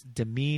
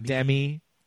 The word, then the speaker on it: Demi: It is pronounced Demi, not Demi.